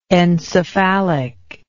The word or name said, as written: encephalic